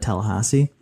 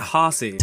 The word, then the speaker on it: Tallahassee: You're saying Hossie. – Tallahassee? – Yeah, don't say it like that.